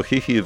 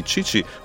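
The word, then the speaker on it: Chichi (父): Tak, no wprawdzie po przyjeździe tam na miejsce do Japonii okazało się, że Japończycy słowo Hihi wymawiają po angielsku, czyli Chichi, ale jakie to w końcu ma znaczenie.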